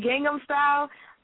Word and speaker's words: Gangnam Style: Okay, Gangnam Style.